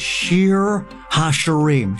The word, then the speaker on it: Shir HaShirim: In Hebrew, it's called Shir HaShirim, Shir Song. It's the Song of Songs.